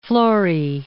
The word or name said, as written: Florey